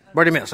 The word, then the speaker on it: Bartimaeus: Bartimaeus, okay.